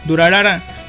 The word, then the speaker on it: Durarara: Durarara, un anime basado en la novela ligera de Narita Ryogo.